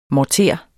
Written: morter

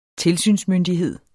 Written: tilsynsmyndighed

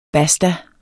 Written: basta

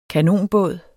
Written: kanonbåd

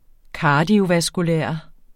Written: kardiovaskulær

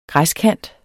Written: græskant

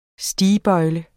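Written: STIGBØJLE

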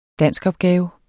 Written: danskopgave

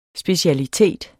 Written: SPECIALITETERNES

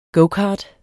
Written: gocart

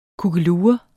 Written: kukkelure

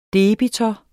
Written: debitor

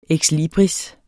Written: ekslibris

